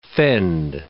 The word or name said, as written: fend